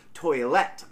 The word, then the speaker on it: toilette: toilette. I believe I said that wrong again. I believe it's... – Toilette.